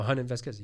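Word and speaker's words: Jhonen Vasquez: I don't actually know how to say it, but Jonan Vasquez. – Oh, Honan Vasquez, yeah. – Honan Vasquez? – Yes.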